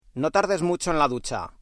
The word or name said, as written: ducha